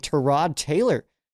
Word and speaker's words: Tyrod Taylor: And if you notice there, I did pronounce his name, Tyrod Taylor, because, well, that's how it's pronounced. And he's been saying this for years. He has been saying it's Tyrod for years. Like four teams ago, he was like, it's Tyrod Taylor.